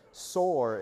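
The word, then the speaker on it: Sor (צור): Sor is how you say that.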